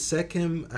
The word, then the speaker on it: Sekhem: Is Sekhem, am I pronouncing it correctly? – Sekhem, yes.